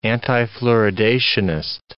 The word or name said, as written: antifluoridationist